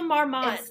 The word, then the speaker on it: Marmont: Gucci Marmont, I believe is how it's pronounced, yeah. Right? – It is a Marmont.